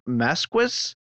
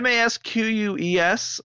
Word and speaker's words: Masques: Cedric LeBlanc said, I heard someone mispronounce Mercadian Masks as Mercadian Masquis. Hard to write down how he pronounced it, but it sounded awful.